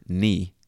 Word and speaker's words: knee: And we spell it K N-E-E. And it was around this time that Middle English was becoming Modern English that people stopped pronouncing the K in the word knee. So the K is now silent.